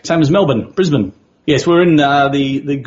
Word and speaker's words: Melbourne: It's not Melbourne. It's Melbourne.